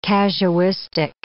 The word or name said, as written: casuistic